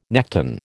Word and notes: nekton: American English

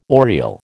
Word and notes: oriel: American English

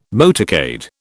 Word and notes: motorcade: British English

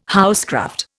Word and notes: housecraft: Indian

